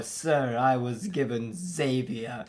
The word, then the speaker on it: Zevia: Your mom goes, it's pronounced Zevia.